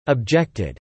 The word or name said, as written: objected